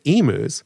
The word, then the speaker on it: emus: Didn't she say, feel free to check out the emus? – Yes, she did. Very good. Now, here's something that's interesting because we pronounce it emus, but in Australia and in England, they'll say emus.